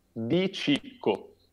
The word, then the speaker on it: De Chico: Is it De Chico or De Chicho? – De Chico. – De Chico. Yeah, I thought so. Because sometimes with the double C, right. – you have to pronounce it Chi. Sometimes. – Yes. – Like a Puccini.